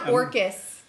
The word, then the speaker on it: Orcas: But it's pronounced Orcas. – But is it spelled O-R-C-A-S? – Yeah.